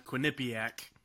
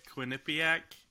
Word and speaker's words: Quinnipiac: Quinnipiac. – I always put the emphasis on the piac, Quinnipiac, but that's just how I read it.